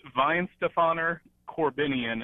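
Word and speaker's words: Weihenstephaner Korbinian: It's pronounced Weinstephaner Corbinian. It starts with a K. And the brewery is called Weinstephaner, and it starts with a W.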